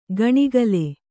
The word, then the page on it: ಗಣಿಗಲೆ: gaṇigale